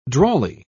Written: drawly

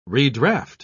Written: redraft

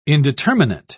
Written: indeterminate

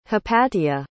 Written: hypatia

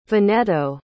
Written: veneto

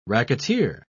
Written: racketeer

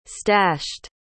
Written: stashed

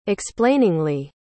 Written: explainingly

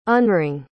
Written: unring

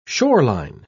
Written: shoreline

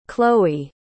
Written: khloe

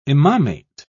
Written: imamate

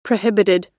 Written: prohibited

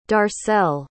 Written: darcell

